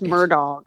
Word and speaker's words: Murdaugh: It's Murdaugh. Like, hey, dog. It's probably okay. Like murder dog, murder dog. – Like in French, it would be like Murdaugh, but they're like countries.